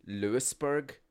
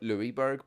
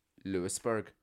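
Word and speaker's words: Louisburgh: There's a place called Lewisburg. But the Lewis is spelled as Louis. So I always call it Louisburg because it is spelled as Louis, L-O-U-I-S. But they pronounce it Lewisburg.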